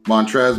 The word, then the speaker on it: Montrezl: Montrez, my bad. Montrez. It's spelled Montrez, but they pronounce it Montrez.